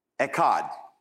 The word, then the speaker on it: echad: The second thing, so day is yom, but the word one is echad. Of course, you have to sound like you're choking a little bit more when you say it.